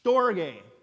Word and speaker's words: storge: We pronounce that one storge. Storge, it's kind of a fun word.